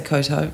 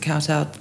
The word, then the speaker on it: kowtow: I say Koto, but most people would pronounce it Kowtow.